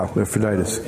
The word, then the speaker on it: Epaphroditus: Epaphroditus. – Yeah, Epaphroditus.